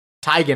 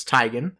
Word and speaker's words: Teigen: Teigen, actually. She's corrected everybody on how to say her last name. It's Teigen.